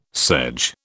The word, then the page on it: sedge: British English